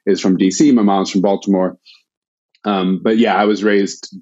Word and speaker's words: Baltimore: People from Baltimore say Baltimore, as if there's a D.